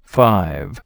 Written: Five

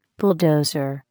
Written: bulldozer

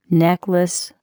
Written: necklace

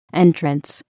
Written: entrance

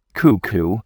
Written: cuckoo

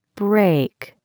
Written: break

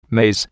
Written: maze

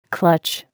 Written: clutch

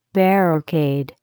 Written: barricade

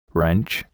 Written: wrench

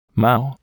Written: mow

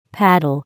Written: paddle

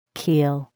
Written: keel

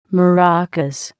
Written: maracas

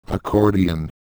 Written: accordion

